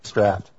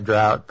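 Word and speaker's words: Draught: No, I'm drinking Guinness Draft. – You said it correctly. I finally harassed you into pronouncing it draft like you're supposed. – You know what? I actually wanted to say drought, but I figured, you know.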